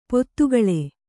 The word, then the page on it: ಪತ್ತುಗೊಳ್: pattugoḷ